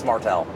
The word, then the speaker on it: Martelle: It's like if someone mispronounced my name every time, and they were like, okay, Martelle, and I'm like, it's Martelle.